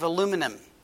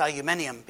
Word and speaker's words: aluminium: A lot of folk have been told that that you have to get rid of aluminum, which we pronounce aluminium because we speak English.